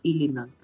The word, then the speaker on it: Illinois: Se dice sin la S. Illinois.